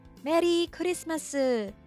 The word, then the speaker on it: Merii Kurisumasu: But instead, they also use the English phrase pronounced with Japanese intonation, which is Merii Kurisumasu, or in short, Merry Kuri.